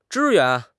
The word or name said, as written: 支援